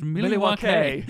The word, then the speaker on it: Milwaukee: Actually, it's pronounced Milwaukee. – Yeah. – It's Native American.